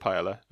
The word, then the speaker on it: paella: Or is it C, creating a range of paella brands similar to Uncle Ben's? I said paella, I meant paella. That's how you say it.